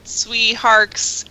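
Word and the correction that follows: Tsui Hark: Vampire Hunters? Did I say that right? – Fucking loved it! – Tushihark, isn't it? – Pronounced in Cantonese, it would be closer to Choi Hawk.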